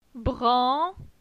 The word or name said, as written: Bran